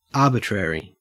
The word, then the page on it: arbitrary: Australian English